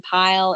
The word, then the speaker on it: Pyle: Pyle. And Madeline is spelled like Madeline, and Pyle is P-Y-L-E.